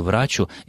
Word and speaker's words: врачу: Ну, честно говоря, Галина Георгиевна, меня поразило то, что вы вообще это словосочетание слышали часто, но если мы обратимся к словарям, то в конструкции «врачу исцелися сам» ударение в слове «врачу» используется только на первом слоге.